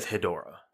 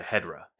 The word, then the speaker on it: Hedorah: So I finally found the actual pronunciation. It's a hard E with a silent O, apparently. – Hard E, silent O. Hedora. Hedora. – Yeah, Hedra. – Hedra.